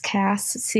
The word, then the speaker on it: Cass: It's my first name, which is Cass, CASS, and then my last name, which is spelled anger, OSA.